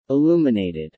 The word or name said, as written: illuminated